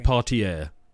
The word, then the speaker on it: Depardieu: Gerard Depardieu, I think. – Depardieu.